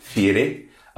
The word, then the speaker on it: Fieri: Well, I mean, yeah, we've obviously got the bleached tips component and the chokers that are the hallmark of Mr. I think it's actually Fieri, if you wouldn't mind pronouncing it with the proper Italian pronunciation.